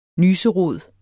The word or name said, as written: nyserod